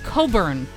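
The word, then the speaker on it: Cockburn: Her last name is Coburn, like the singer Bruce Coburn.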